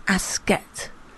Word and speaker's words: Ascot: I pronounce it how it's spelt. The last time I checked, there was not a U in Ascot, there was an O.